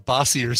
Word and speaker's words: Bossier: It looks like it should be pronounced Bossier City. – Yeah. They said, well, that I would have said that, except there it's Bossier. – You know it's Bossier.